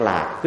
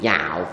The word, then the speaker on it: lạc: Trong cái nghi thức quá đường của quý thầy quý sư cô đó, nhược vị lạc cố thí hậu tất đắc an lạc. Quý vị dịch sai hay đọc là nhược vị nhạo cố thí đó. Nhạo có nghĩa là thích, ở đây không phải là nhạo quý vị, đây là lạc.